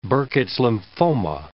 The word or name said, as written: Burkitt's lymphoma